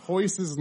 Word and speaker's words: Royce: Is that spelled with an R? – Yeah. – Yes. – It is. – And you're saying hoist, though? – Yes. – So, but it's spelled with an R, though. – Everybody in their family. – That is correct. – Everybody in their family, their name begins with an R, and it doesn't begin with an R at all.